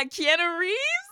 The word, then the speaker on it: Keanu Reeves: And saw Keanu Reeves. I'm sorry, am I saying his name right? – Yeah, Keanu Reeves.